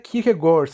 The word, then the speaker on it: Kierkegaard: Ee, Søren Kierkegaard. Aslında Kierkegaard sanırım tam okunuşu.